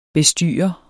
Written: bestyrer

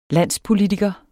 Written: landspolitiker

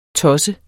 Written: tosset

